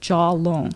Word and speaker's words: jiao long: But an old doctor told everyone about the jiao long, and I have it in Chinese, and then in parentheses I say pronounced jaw long, like jaw as in your lower jaw, and long as in money that you would get.